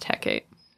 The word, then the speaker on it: Hecate: But I had only ever heard it pronounced Hecate. That's how modern witches say it.